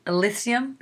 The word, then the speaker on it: Elysium: So I had read that um, I'm pronouncing that that Matt Damon movie is El- Elysium.